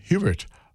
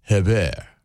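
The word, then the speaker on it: Hébert: Fred's on piano, John Hubert on bass, and this is his. He pronounces his name in French enunciation. So let's do it right, John Hebert, okay, and Eric McPherson on bass and drums, respectively, for 2016.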